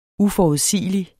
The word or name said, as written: uforudsigelig